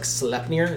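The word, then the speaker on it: Sleipnir: I don't think it's Slipnir. I think it's like Slepnir is how it's pronounced, and I could not find what that is anywhere.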